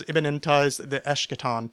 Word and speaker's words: immanentize the eschaton: I mispronounced that. That very long polysyllabic thing was immanentize the eschaton.